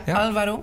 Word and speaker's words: Alvaro: Moet ik het goed uitspreken hoor. Dat is Alvaro. – Ja, Alvaro, inderdaad. Ja, dat is te gek.